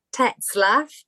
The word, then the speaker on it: Tetzlaff: My name's Sass Tetzlaff. I know it's a bit of a long word to sort of get your tongue around, but it's German and you pronounce every letter that's there. So it's Tetzlaff.